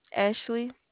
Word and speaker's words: Ashley: Ashley. – Can you spell it? – A-S-H-L-E-Y.